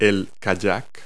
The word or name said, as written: kayak